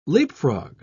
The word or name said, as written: leapfrog